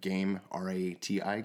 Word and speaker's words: Gamerati: And we've brought on great partners, Ed and Ben from Gamerati. I hope I'm saying that right. Game, R-A-T-I, Gamerati? – Yeah, Gamerati.